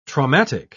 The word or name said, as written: traumatic